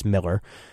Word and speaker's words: Millar: It's not Millar, it's Miller.